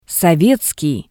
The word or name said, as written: sovetskii